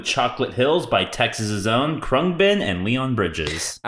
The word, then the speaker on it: Khruangbin: Khruangbin. – Khruangbin. Khruangbin.